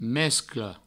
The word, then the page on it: mescla: mesclo